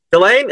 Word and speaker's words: Ghislaine: Is it Gillian or Gillian? I can't pronounce her name, so I haven't talked to her. – Oh, Ghislaine? Ghislaine? – Yeah, Ghislaine is Ghislaine.